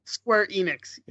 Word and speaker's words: Square Enix: Square Enix, is that how you say it? – Square Enix, yeah.